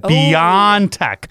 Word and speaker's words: BioNTech: That's how you say it. BioNTech. I've been calling it BioNTech. And it's just spelled differently, but it's BioNTech. Isn't that a cool name?